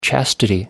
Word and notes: chastity: American English

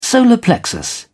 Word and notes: solar plexus: British English

